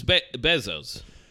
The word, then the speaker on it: Bezos: It's Bezos.